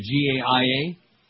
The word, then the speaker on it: Gaia: He called it, how do you say it? G A I A? Gaia.